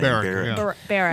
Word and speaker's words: Barrack: Barrack.